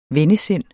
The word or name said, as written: vennesind